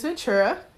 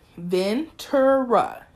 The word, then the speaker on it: Ventura: My name is Ventura. Ventura. V-E-N-T-U-R-A.